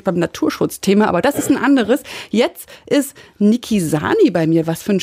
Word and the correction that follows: Naki Sani: Habe ich dich richtig ausgesprochen? – Nein. – Na, sag mal, wie heißt du richtig? – Naki Sani. – Naki Sani.